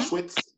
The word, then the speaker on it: Auschwitz: Auschwitz, if I'm saying that right? – Auschwitz. – Yep.